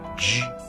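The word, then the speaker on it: 之: Слово «дж» произносится в первом тоне.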